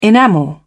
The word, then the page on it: enamel: British English